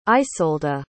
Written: isolda

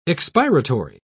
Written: expiratory

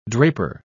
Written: draper

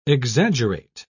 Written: exaggerate

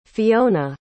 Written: Fionna

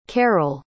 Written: caryl

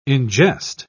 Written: ingest

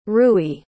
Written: rui

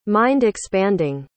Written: mind-expanding

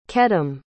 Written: Kedem

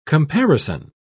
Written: comparison

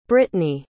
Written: britny

